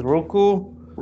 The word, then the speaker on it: Roku: And why are you saying Roku weird? – It's Roku. – Roku. – That's how you say it.